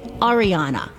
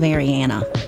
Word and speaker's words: Ariana: So I should be saying Ariana instead of Ariana. Ariana Grande.